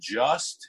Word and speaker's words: JU$T: There's a song on the album called Just, and it's spelled JU$T.